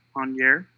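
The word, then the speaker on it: Angers: On year.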